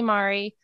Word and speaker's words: Marri: The accent's on the first syllable, but you guys can just call me Marri. I tell people rhymes with sorry.